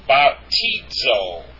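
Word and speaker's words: baptizo (βαπτίζω): That's the zeta there. It's the DZ sound. It's correctly pronounced baptizo. Notice where the accent is.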